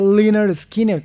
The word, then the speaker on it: Lynyrd Skynyrd: se pronuncia Lynyrd Skynyrd, como para que la gente supiera realmente cómo se pronunciaba el nombre de la banda.